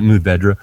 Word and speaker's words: Mourvèdre: Monastrell is what the Spanish call Mourvèdre. Most people pronounce it Mourvèdre, and that's grown like in the Rhone and Provence, southeastern Spain, which is Humea.